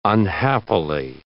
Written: unhappily

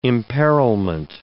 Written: imperilment

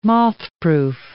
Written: mothproof

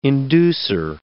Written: inducer

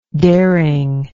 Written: daring

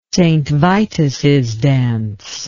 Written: st vituss dance

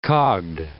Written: cogged

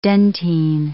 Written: dentine

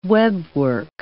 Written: webwork

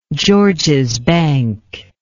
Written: Georges Bank

